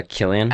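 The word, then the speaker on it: Cillian: And I love that for, is it Killian? Is that how you say his name? – Yeah, Killian.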